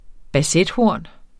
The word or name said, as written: bassethorn